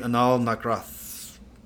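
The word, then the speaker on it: Anal Nathrakh: So my next one would be Anal Nakroth. Okay. Like that's how you fucking pronounce it.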